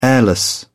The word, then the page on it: airless: British English